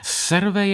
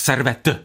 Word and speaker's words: Servet: Takže, kdybychom to zkusili nějak zjednodušit, Serve je unitář. Serve je, to vysvětlu francouzsky, polikám to, to není moje špatná výslovnost. Dobře, tak Servet, budu říkat Servet.